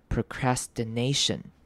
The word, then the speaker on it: procrastination: Procrastination，好，那的这个声音后面接到的 i 记得念成短母音 i did procrastination。好，注意到这个字的重音在 a 这个声音上面，所以会是念 procrastination。